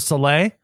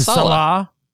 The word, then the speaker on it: Saleh: Is it Robert Saleh? I always thought it was Saleh. – I think it's Salah. – Salah? – Salah.